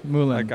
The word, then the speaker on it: Moulins: Moulins. – Think of Moulin Rouge. Almost. – Don't pronounce the S. Moulins.